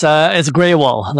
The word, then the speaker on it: Gruhl: It's Graywall, like a gray color Graywall.